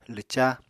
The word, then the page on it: lwca: Apatani